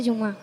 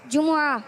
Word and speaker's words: جمعہ: جمعہ. – ہاں, کچھ لوگ کہہ رہے تھے جمعہ جمعہ جمعہ, صحیح تلفظ نہیں ہے. صحیح تلفظ کیا ہے, صحیح ادائیگی کیا ہے؟ جیم پر بھی پیش ہے, میم پر بھی پیش ہے.